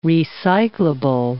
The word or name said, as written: recyclable